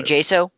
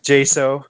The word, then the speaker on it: Jaso: I think, Delk, Clark was a for us the year before, and either Spikes or Manny Zhao, or how you pronounce that. – Manny Jaso. – Jaso.